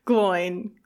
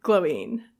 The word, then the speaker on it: Glóin: But it would be nice to tweet at him and be like, how do you pronounce Gloin? Glóin. How do you do it? So point being is that I think either pronunciation could be accepted because the movie pronounced it Glóin and they had all of those Tolkien experts there and blah, blah, blah.